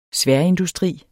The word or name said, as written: sværindustri